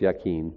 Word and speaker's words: Jachin: So it looks like it says Jake, and it's Yakin. That's how you pronounce it. No J sound.